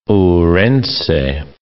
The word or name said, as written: Ourense